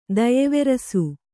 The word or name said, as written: ದಯೆವೆರಸು